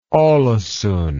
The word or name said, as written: alesund